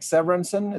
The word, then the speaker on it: Severinsen: I'm the only one in the world called Stig Severinsen. It's spelled S-T-I-G, like the stick, if you know, from the UK, the driver with the helmet on. And then my last name, S-E-V-E-R-I-N-S-E-N, Severinsen.